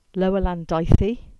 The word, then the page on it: Lower Landithy: English